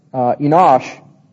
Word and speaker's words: Enosh: Enos, which is in the Hebrew pronounced Enosh, comes from the same root as the word Anosh, meaning mortal or incurable.